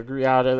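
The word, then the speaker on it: Greada: And pretty much what happened was the Griotta Treaty, the Griotta, that's how you pronounce it.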